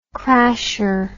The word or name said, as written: crasher